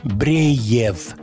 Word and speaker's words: Breev: Следующий трек загадочной группы, название которой пишется латинскими буквами B-R-E-E-V. Бреев.